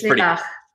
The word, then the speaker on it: Lilach: It's pretty close. It's Lilach. But Lilach. That'll do. Honestly, don't worry about it.